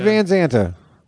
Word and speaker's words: Van Zandt: Aren't all Van Zandt's bandana people? – Yeah, it rhymes with bandana. Stevie Van Zandt with his bandana.